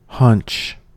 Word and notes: hunch: American English

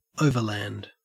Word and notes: overland: Australian English

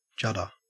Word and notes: judder: Australian English

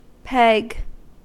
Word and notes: peg: American English

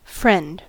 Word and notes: friend: American English

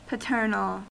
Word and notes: paternal: American English

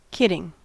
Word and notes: kidding: American English

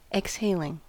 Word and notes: exhaling: American English

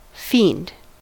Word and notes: fiend: American English

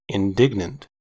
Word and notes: indignant: American English

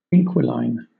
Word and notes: inquiline: British English

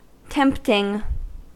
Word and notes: tempting: American English

